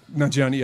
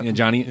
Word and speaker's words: Nanjiani: Nanjiani, yeah. – Nanjiani.